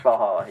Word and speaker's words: Valhalla: Valhalla. Right? I said it right. It's not right. – Valhalla, yeah.